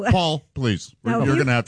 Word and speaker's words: Poll: P-O, it's pronounced Paul, there's a but it was spelled P-O-L-L, as in pole. – Mm. Paul, like a boating pole, yeah.